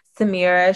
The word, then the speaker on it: Samira: I'm really excited for us to welcome Samira Sharif. And let me know if I'm saying it correctly. – Yes, you are fine. It rhymes with Amira.